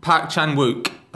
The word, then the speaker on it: Park Chan-wook: It's basically Park Chan-wook, how you pronounce it, the guy who did the Vengeance trilogy and Thirst and stuff like that. Some really great Korean films. He is making his English language debut with Stoker, which has got Nicole Kidman, Mia Wasikowska, and Matthew Goodman. – That was a good pronunciation.